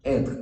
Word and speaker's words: être: être. Nós pronunciamos assim, le verbo être.